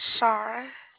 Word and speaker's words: Shara: Shara. – Okay. How do you spell that? – S-H-A-R-A.